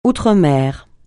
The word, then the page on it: outremer: francais